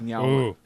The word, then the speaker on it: New Orleans: It's pronounced Nyalins.